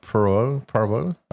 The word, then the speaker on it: Poirot: Hercule Poirot.